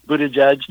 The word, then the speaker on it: Buttigieg: Pete Buttigieg, or how do you say his name? – Buttigieg,